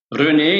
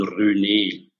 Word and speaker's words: René: René, in der Schweiz natürlich René, aber im deutschsprachigen Raum sagt man René. Das ist ein französischer Name und entsprechend René.